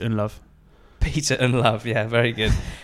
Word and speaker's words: Ndlovu: Peter I, Peter Ndlovu, I only knew from like Champ Man when I was really young, and before you know how to pronounce that, you're saying Unlovu.